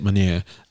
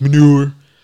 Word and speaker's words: Meunier: That's how you say it, Meunier. I hate when people like manure.